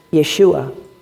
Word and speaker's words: Yeshua: Hebrew base, which is called Joshua, or the way that you actually pronounce it is Yeshua.